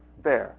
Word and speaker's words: Baer: how one properly pronounces this? Bear. Okay, so I've always pronounced it correctly.